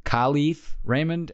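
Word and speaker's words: Kalif Raymond: Khalif Raymond, Mike Tirico, if you're listening, that's how you pronounce his name.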